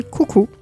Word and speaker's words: coco: Elles s'inspirent du coco et prononcé coucou. Donc elle s'inspire du coucou, une musique populaire du nord-est du Brésil.